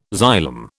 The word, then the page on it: xylem: British English